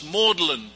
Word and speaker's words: Magdalen: It's pronounced in Oxford as Maudlin College, but it's really spelled Magdalen.